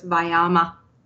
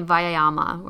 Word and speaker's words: Vyayama: And we think about it in three syllables, Vyayama. So it's Vyayama. So flows better than it looks when you read it. – Right. I won't say Vayama or something like that, but Vyayama.